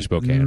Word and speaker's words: Spokane: We're going to talk a lot about your time in Spokane, Washington. – Mm-hmm. – It's actually Spokane, mm-hmm. right? – Mm.